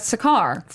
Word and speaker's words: Sakaar: The planet Sakaar.